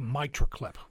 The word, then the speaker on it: mitral: All right, I want to move on to another one, a mitral clip.